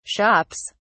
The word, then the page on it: shops: British English